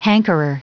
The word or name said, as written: hankerer